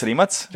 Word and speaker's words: Rimac: How would you pronounce it exactly? – Well, in Croatia, Rimac. It's Rimac.